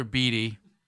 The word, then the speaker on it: Beatty: Is Tyler's name pronounced Beatty, Dano? – Yep. – So they bring in Tyler Beatty, and they have Shane Bieber and Tanner Bibee.